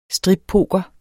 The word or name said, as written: strippoker